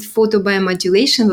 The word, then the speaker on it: photobiomodulation: And I studied photobiomodulation, like you rightly correctly pronounced it.